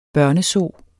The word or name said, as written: børnezoo